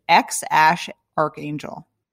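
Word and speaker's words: X Æ A-12: So it's X, A-E, A12 is the baby's name, but you don't pronounce it X, A-E, B. X A E A 12. You pronounce it X Ash, and then what's the last part is also code for something, right? – So A-12 is thought to be in reference to the CIA aircraft Lockheed A-12, which was codenamed Archangel. So this kid's name is apparently pronounced as X Ash Archangel.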